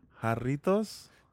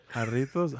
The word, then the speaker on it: Jarritos: You don't pronounce the J. – Oh, hold on. Jarritos? – There you go. – I'm sorry, I didn't roll it. – Jarritos?